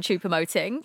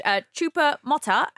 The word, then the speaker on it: Choupo-Moting: Martin Keown calling him everything other than Choupo-Moting throughout the match. Choupo-Mota and Choupo-Mota.